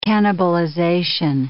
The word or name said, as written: cannibalization